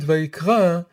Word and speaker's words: Vayikra: And if you look closely at the word Vayikra, you see that something unusual is going on about it. Its final letter, which is the letter Aleph, it's a silent letter. You don't hear it.